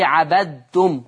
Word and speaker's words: عبدتم: طول ما الحرف الأولاني عاري من التشكيل ما عليهوش أي حاجة، والحرف اللي بعد منها مشدد، اعرف إن الحرف اللي ما عليهوش تشكيل لا ينطق، والحرف المشدد هو الذي ينطق. فعشان كده اوعى تقول لي عبدتم وبين الدال، نطقك للكلمة دي ت خالصة تاء.